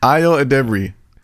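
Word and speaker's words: Ayo Edebiri: Ayo Adebri. – Ayo Adebri. – Yeah. – Ayo so Adebri. My Juneteenth. All right. Here we go. My Juneteenth is Ayo Adebri.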